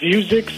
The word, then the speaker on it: Vuzix: Well, the stock I'm interested in, Jim, is I believe it's pronounced Vuzix, V-U-Z-I-X, symbol V-U-Z-I.